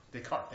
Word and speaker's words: Descartes: See, modern thinking of understanding is like a, you know, the old philosopher René Descartes. How do you say it? I'm terrible with those French words. Descartes, thank you.